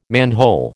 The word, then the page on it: manhole: American English